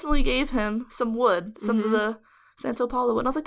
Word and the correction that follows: Palo Santo: Palo Santo. Pa- I don't know why I can't say it right. Santa Paul. It's because you're used to like Santa Ana.